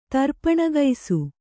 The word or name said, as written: ತರ್ಪಣಗೈಸು